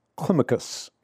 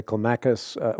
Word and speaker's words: Climacus: Climacus is how it's typically pronounced, but a lot of people say Climacus.